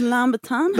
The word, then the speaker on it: Louboutin: I won't take it from the from the from the from the woman who wears like Louboutin slippers. – It's actually pronounced Lambertan.